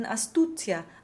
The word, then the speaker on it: astuzia: Then we have the Z's in astuzia, arguzia, and giudizio. Simone said that when a Z is followed by an I plus another vowel, the Z will be doubled, so phonetically T-T-S.